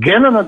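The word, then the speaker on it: Kennan: как произносить правильно, я не знаю, Кеннон институт. – Кеннона, да, институт, The Cannon Institute.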